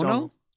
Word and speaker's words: Domhnall: Donal. – We watched Donal? – Donal. – Yeah, Donal. You pronounce it Donal.